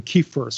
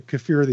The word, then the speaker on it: kefir: I grew up saying kefir, so inside I kind of wince when I say kefir these days, but that's apparently the correct way to say it.